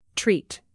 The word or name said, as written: treat